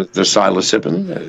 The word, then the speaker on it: psilocybin: I mean, the psilocybin? – We're limited, so we only get these gold-capped things. I think they're psilocybins or whatever. – Psilocybin, let's pronounce it correctly.